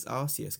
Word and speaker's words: Arceus: I think it's Arceus because it's a soft C. It's not... – Arceus. – A hard C.